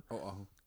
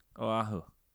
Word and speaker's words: Oahu: Oahu. – Oahu.